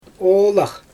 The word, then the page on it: oolax: Xyzyl